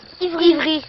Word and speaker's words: Ivry: Ivry. – La dernière prononciation est la bonne.